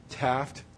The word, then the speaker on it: TATFT: This is something you guys probably aren't as familiar with, but T-A-T-F-T, pronounced Taft, because the T is silent, stands for testing all the effing time.